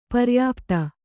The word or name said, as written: ಪರ್ಯುಪ್ತಿ